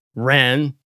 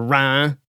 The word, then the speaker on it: Reims: But then you've got Stade Reims, which is also pronounced Rennes or Rhin. It's very confusing.